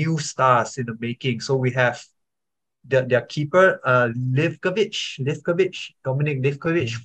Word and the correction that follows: Livaković: from a team from, I'm not quite sure, Dinamo Zagreb, sorry. So Dominik Livaković, I'm sorry for the pronunciation.